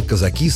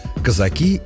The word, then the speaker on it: казаки: При этом часто задается вопрос, как правильно произносить слово «казак» во множественном числе – «казаки» или «казаки». И в старинных рифмованных поговорках, и в стихах слово звучит по-разному. Словари также считают правильными оба варианта.